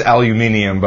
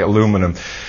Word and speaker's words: aluminium: The correct pronunciation is aluminium, but we tend to say aluminum.